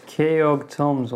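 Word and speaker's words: Keoghtom: It's spelled K-E-O... G-H-T-O-M-S. It's a magic item. – Slav. – It's called Kegorm's Ointment. – Keog Tom's Ointment.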